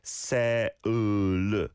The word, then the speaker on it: Seoul: Halsey Reno's on board saying in Brazil, the sole pronunciation doesn't even get this R sound. It's something like se-u-l.